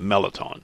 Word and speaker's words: mirliton: It's spelled Merliton, M-I-R-L-I-T-O-N, but most people pronounce it melaton.